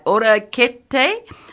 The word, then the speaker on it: orecchiette: And this one, and I know this, I'm going to say this right, orakete, with roasted cauliflower, pine nuts, and green olives. And I looked it up, and that's the pronunciation, although it doesn't spell like that. It spells like O-R-E-C-C-H-I-E-T-T-E.